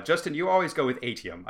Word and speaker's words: Atium: And so ATM is how it's pronounced in the audiobooks.